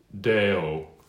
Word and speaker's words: Deo: Excelsis, sort of like eggshells, but I like an EK, excelsis, excelsis Deo, not Deo, Deo, toward, you know, the short E, de, toward that, okay?